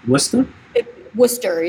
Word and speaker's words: Worcester: Worcester, yeah.